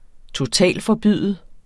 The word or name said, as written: totalforbyde